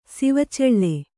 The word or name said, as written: ಸಿವಚೆಳ್ಳೆ